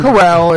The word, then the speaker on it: Corral: Corral is how I pronounce it.